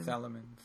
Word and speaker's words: Salamence: Yes, Mega uh, Salamence. I assume. – Salamence.